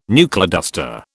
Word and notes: knuckleduster: British English

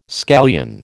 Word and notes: scallion: American English

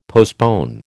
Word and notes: postpone: American English

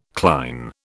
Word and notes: cline: British English

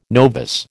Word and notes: novice: American English

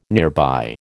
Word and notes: nearby: American English

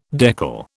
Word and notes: decal: British English

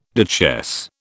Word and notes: duchesse: British English

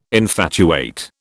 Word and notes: infatuate: British English

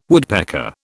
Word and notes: woodpecker: British English

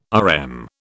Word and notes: RM: British English

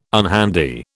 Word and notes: unhandy: British English

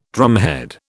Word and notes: drumhead: British English

